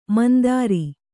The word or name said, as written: ಮಂದರ